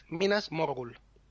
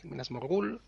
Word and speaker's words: Minas Morgul: Incluso cuando los nombres son de varias palabras, de dos sílabas, lo mismo. Minas Tirith, Minas Morgul. No Minas Tirith, Minas Morgul, etc.